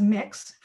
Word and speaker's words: Mx: And if you identify, for example, as gender non-binary, on the transcript, you will be referred to as MX and that's pronounced as mix for those who don't know.